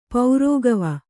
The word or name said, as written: ಪೌರೋಗವ